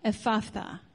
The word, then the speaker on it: Ephphatha: He looked up to heaven and with a deep sigh said to him, Ephphatha.